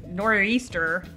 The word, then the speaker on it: nor'easter: Liberty's in the middle of a crazy nor'easter. – I pronounced the apostrophe there. Did you hear it? Nor'easter.